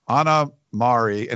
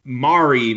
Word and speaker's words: Mari: So her name is Anna, but it's A-N-A. And then it's M-A-R-I. It's pronounced like calamari, Mari. Like, so Anna Mari, and it's Kause, her last name.